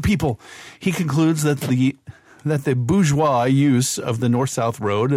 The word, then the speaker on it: bourgeois: If you're going to say it appropriately, I didn't quite nail it because you need to almost choke at the end. Bourgeois.